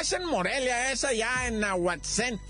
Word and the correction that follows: Nahuatzen: A lo mejor estoy pronunciando mal, ¿verdad? Y se pronuncia Nahuatzen, ¿verdad?